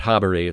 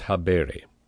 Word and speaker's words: habere: Now, things that the seminarians are going to want to pay attention to, in no particular order, is, first of all, to make sure that you pronounce the infinitive habere correctly. It's not habere, it's habere.